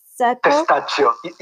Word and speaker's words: Testaccio: How do you say Testaccio? – Testaccio. Yes, it's Italian.